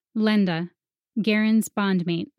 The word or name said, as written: Lenda